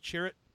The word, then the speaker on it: Chirrut: Chirrut? Imwe. – Yep, what you said. – All right, I'm fine with where you went. Chirrut.